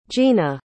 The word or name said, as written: jeana